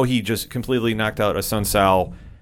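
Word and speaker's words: Assunção: After that, the co-main event was was the final bantamweight matchup of the night between Cody Garbrandt, who defeated Rafael Asakao, if I mispronounced that, who shot clock cheesed the knockout at 4 minutes and 59 seconds in the second round. – A Sun Sal.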